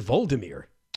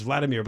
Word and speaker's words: Volodymyr: It was a satirical comedy series starring Ukraine's now president of, I think people pronounce it Volodymyr. – Yeah, it's the Ukrainian version of Vladimir. – Oh, it's really, okay. Yeah, because I've heard it pronounced Vladimir, but I look at it, it's V-O-L-O.